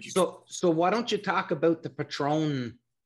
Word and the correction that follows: Patreon: Am I saying that right? Patron? – Patreon.